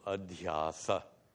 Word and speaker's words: adhyasa: The Sanskrit word actually seems more simple, adhyasa. Adhyasa, three syllables, versus superimposition, six, proving that Sanskrit is simpler than English.